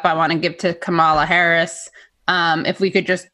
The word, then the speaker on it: Kamala: I think it's Kamala. – All right, Kamala.